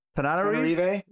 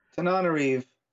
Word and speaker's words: Tananarive: Reginald Hudlin, Pat Charles, Tanarive? – Tananarive? – Yeah, there was an extra syllable in there I missed. Yeah. Apologies if I'm butchering that. Tananarive Du. – Tananarive.